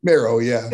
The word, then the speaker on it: Mero: How do you actually, you pronounce it Mero, correct? – Mero, yeah. – Yeah, you were Mauro for about 4 seconds. I'll never forget it. I'm like, what's he doing? I'm like, I don't think you pronounce it that way.